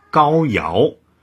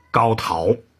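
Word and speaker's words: 皋陶: 任刑部尚书的时候，就经常把上古时代传说当中帮助尧治天下、分管司法的高尧读作高陶，并经常还简称为陶大人。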